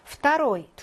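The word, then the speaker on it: 프타로이 (второй): 프타로이. 두 번째의 발음할 때 역행동화 일어나서 프타로이라고 있고요.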